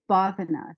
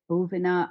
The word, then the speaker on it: bhavana: And the word is bhavana. Some people say bhuvana.